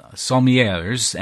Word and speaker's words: sommelier: Did I pronounce that right? Sommeliers? – Sommeliers. – I didn't know if the L was silent or not. And even water sellers, yes. – No, you say the L. Sommelier.